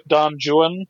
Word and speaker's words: Don Juan: But I would go with Don Juan, which that's how he pronounced it, not Don Juan. It was Don Juan, and you have to say it that way for the rhymes to work.